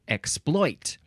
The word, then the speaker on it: exploit: And it looks like same pronunciations, but it looks like the more common pronunciation would be exploit, with the second syllable emphasized.